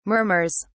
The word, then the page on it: murmurs: British English